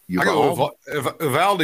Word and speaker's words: Uvalde: How do you say the name of that city? – Uvalde is what they've been saying on TV.